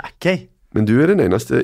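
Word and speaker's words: Aké: Eh, og riktig uttale er Nathan Ake. Nathan Ake.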